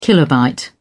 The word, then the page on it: kilobyte: British English